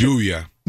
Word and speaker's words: lluvia: Lluvia. – Lluvia? – Lluvia. – Lluvia. – L-L-U-V-I-A. Lluvia. – Ah, thank you. I was trying to say it with a Y, not an L.